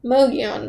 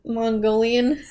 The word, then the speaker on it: Mogollon: I keep trying to call it Mongolian. – I do that all... Like, when I was at scout camp, I was like, Carly, what the frick? How do you say that? Like, I always wanted to say Mongolian. But there's no I in there.